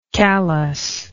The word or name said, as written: callousness